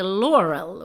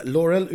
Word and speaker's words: Laurel: Hon heter Laurel och inget annat. – Nej, precis. Laurel uttal eh, med betoning på första stavelsen.